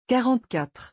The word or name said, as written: 44